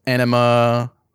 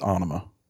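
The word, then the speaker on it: Ænima: So this album, is called, Anima. That's how it's pronounced. Everywhere. There's going to be people saying that it's Anima. Or Anima or whatever the fuck they want to say. – I've heard multiple ways, but I'll tell you right now, Adam Jones, the guitarist, says it's Anima.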